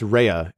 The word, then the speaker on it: Raya: So the budget for Raya and the Last Dragon, I keep on saying Raya. It's Raya. It's pronounced Raya, even though it's spelled R-A-Y-A.